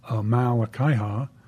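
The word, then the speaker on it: Amalickiah: Today we tend to put the emphasis on the all part of the name. While we generally pronounce the name as Amalekihah, Joseph likely pronounced it Amaluka.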